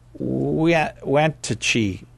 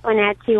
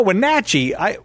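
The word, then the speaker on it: Wenatchee: We go now in East Wantachie, Washington. Did I just butcher that, didn't I? – Yes, but that's okay. East Wantachie, Washington. – Oh, Wantachie.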